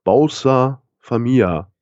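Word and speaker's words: Bolsa Família: Die heißen Bolsa Familia, nee, Bausa, Bausa Familia, so spricht man es aus.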